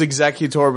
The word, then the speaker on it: Executor: But no, it's the correct pronunciation is Executor, but I choose to use the wrong pronunciation because it sounds cooler.